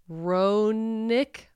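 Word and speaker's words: Roenigk: I can't say this last name. It's R-O-E. N-I-G-K. Ronick. I'm going to pretend the G is silent.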